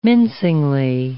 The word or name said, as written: mincingly